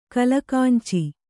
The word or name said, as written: ಕಲಗಂಚು